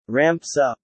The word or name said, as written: ramps up